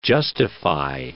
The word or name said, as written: justify